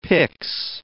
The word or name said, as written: pix